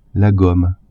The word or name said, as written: gomme